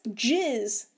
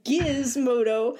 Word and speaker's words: Gizmodo: And then like one of the games, someone was like, "Oh, Giz," and they're like, "No, that's Jizz." And I'm like, "What the fuck?" That is, they're like, Gizmodo. I'm like, no, that is a hard G. It is always Gizmodo.